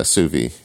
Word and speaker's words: sous vide: Yeah, sous vide.